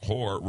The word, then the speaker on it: hoar: The word H-O-A-R, whore, refers to its white color.